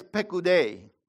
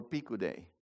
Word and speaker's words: Pekudei: These are the accounts, Pekudei or Pikudei, of the tabernacle of the testimony, Hamishkan Mishkan Ha'edut. Exodus 38, verse 21. This word Pekudei, okay, Hebrew Pekudei, can also be pronounced Pikudei, with an I, Pikudei.